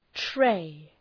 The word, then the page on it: tray: treı